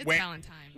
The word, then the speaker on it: Valentine's: Wendy, I don't know what school you went to, but it's Valentine's. – Wendy and Griffin? – Yeah, I definitely say Valentine's Day, and I think my mom said it wrong all along growing up. She said Valentine's Day, and I didn't learn until solidly in college that people actually said Valentine's Day.